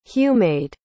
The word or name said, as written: humayd